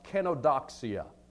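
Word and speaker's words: kenodoxia (κενοδοξία): That word, vain glory, is a Greek word, and it's pronounced kenodoxia.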